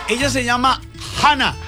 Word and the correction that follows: Jhanna: No, Yana. Le hago la Yana, director. Yana. – Yana, con J. – O sea, se escribe J-H-A-N-N-A.